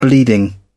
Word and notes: bleeding: British English

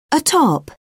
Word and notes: atop: British English

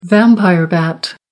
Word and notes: vampire bat: American English

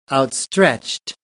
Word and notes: outstretched: American English

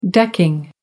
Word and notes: decking: American English

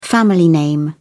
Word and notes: family name: British English

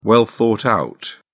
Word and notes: well thought out: British English

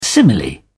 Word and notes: simile: British English

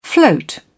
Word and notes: float: British English

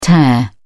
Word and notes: tare: British English

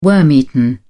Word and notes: worm-eaten: British English

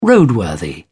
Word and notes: roadworthy: British English

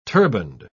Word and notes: turbaned: British English

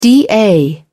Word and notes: da: English; British English

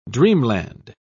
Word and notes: dreamland: British English

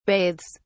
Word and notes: bathes: British English

